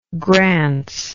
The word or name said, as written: grants